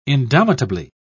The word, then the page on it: indomitably: British English